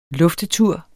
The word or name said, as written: luftetur